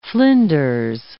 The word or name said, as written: flinders